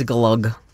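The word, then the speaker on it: glögg: It is glug. And I'll spell that for you, glug. And it's G-L-O. You don't need the umlaut. G-L-O-G-G.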